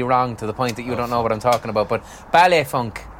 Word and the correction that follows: baile funk: Baile funk.